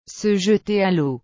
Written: se jeter a leau

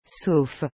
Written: sauf

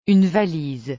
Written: une valise

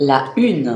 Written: la une

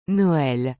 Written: Noël

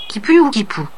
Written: quipu

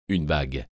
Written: une bague

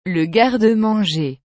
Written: le garde manger

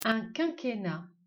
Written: Quinquennat

